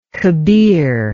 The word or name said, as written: kabir